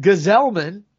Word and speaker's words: Gsellman: Robert Gsellman, That's not how his name looks, but it is pronounced that way, folks.